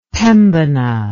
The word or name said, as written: Pembina